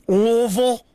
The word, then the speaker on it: Louisville: I'm saying that right, Louisville? – Louisville? It really doesn't matter, man. If you're from here, though, you pretty much drop the I and the S part of it, though, in the Louis.